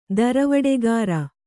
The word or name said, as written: ದರವಡೆಗಾರ